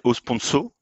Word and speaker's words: Osponso: Bah, alors, je, me permets juste une petite correction sur la prononciation, c'est au sponsor,